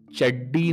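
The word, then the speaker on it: Chaddi: चड्डी में आधा ड और आता है और इंग्लिश में स्पेलिंग होती है C H A D D I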